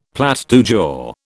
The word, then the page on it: plat du jour: British English